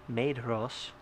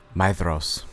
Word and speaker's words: Maedhros: Fingon, the eldest son of Fingolfin, rescued Feanor's eldest son, Maedhros.